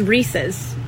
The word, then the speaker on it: Reese's: A pet peeve that I have that I'll never get over is people pronouncing it Reese's. It is Reese's. It is a guy named Reese, and it is his. It's his Reese's. And it's in the commercial. Reese's Puffs, Reese's Puffs.